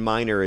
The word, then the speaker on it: Miner: It's and Minor is spelled M-I-N-E-R.